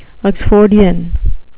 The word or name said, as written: oxfordian